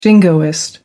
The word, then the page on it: jingoist: American English